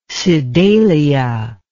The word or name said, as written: Sedalia